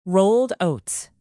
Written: rolled oats

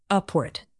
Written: upward

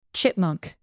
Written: chipmunk

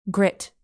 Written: grit

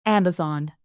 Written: amazon

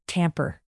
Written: tamper

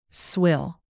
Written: swill

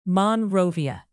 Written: monrovia

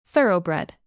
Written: thoroughbred

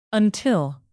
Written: until

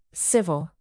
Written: civil

